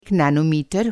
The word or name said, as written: नैनोमीटर